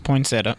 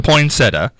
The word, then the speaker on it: poinsettia: Poinsettia. – No, it's not poinsettia. – It's poinsettia.